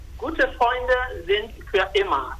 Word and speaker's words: Gute Freunde sind für immer: en alemán se pronuncia Gute Freunde sind für immer.